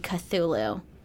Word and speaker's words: Cthulhu: I've always pronounced it Cthulhu, but after looking into a little bit of him for this episode, I saw that it was meant to be Cthulhu. Cthulhu, but I like Cthulhu.